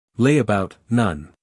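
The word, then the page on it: layabout: uk ˈleɪəbaʊt; en-us ˈleɪəbaʊt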